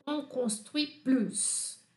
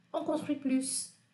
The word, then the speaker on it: on construit plus: a gente vai pronunciar esse S, ele tem som forte. Sans, on construit plus.